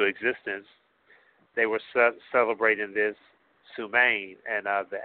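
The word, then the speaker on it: Samhain: but it's called Sumhain. Sumhain. And the spelling of that, if you had your pen with you, is S-A-M-H-A-I-N. S-A-M-H-A-I-N.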